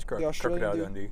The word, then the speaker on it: Crocodile Dundee: Yeah, this is Crocodile Dundee.